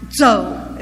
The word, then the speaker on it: Chow: The pastor of that church is Mr. Leon, and we spell it C-H-O-W, but it is pronounced Zhou, as best I can say it.